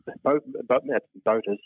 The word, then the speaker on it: Boötes: It's spelled B-O-O-T-E-S. I think that's how you pronounce it. – Yeah, the straw hats people wear when they go to private schools, isn't it? – Boötes, yes.